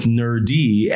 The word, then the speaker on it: NurD: So I went with NurD and originally it was spelled N-E-R-D. Now it's spelled N-U-R-D. But originally it was N-E-R-D, NurD.